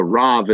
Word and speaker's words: Rav: The Rav is the rabbi, the rabbi. It's R-A-B in the original language, Rav, but it's pronounced, the B is pronounced like a V in Hebrew and Aramaic.